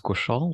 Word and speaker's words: Kushal: Yeah, so my first name is Kushal, which is spelled K-U-S-H-A-L. My last name is Kadakia, which is K-A-D-A-K-I-A, and you can just call me Kushal.